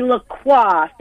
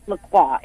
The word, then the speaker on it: Lacroix: It's actually LaCroix. Thank you for asking. It, it, looks like LaCroix, but it's pronounced LaCroix. It is French.